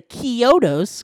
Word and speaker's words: Chiodos: Okay, so back to the Chiodos, because that's how they pronounce it.